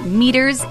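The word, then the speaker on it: Meater: By the way, meters is spelled M-E-A-T-E-R-S, like meat-ers, get it?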